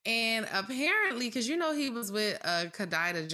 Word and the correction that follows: Kidada: Kidada Jones. Yeah. – Kidada Jones.